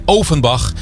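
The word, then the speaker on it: Ofenbach: Klemtoon wel goed leggen, hè? Ovenbach.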